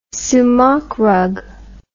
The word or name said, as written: soumak rug